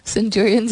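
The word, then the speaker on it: Centurions: आप उसको सेंचुरियंस पढ़े सौ जो बनाते हैं सेंचुरियंस ही कहा जाता है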